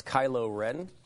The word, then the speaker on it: Kylo Ren: That's Kylo Ren? Is that the way you pronounce it? – Yes.